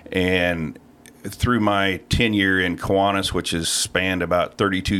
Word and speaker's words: Kiwanis: Did I mispronounce that? – It's Kiwanis. – Kiwanis. Kiwanis. – That's the way everybody else pronounces it.